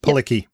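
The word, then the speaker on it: Palicki: And Adrian Pilecki, that's how you pronounce her name, right? – Pilecki, yeah.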